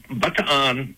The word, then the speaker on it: Bataan: When Bataan, and that's the way the Filipinos pronounce it, that's why I say that, it was surrendered by King.